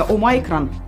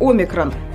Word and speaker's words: Omicron: В то же время новый оксфордский словарь предлагает другой вариант произношения Омайкрон. – Omicron. – В греческом же языке, то есть в источнике, это слово произносится с ударением на первый слог «омикрон».